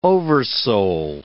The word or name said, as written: oversoul